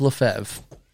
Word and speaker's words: Lefebvre: The bishop who was named Archbishop Lefebvre was a heretic.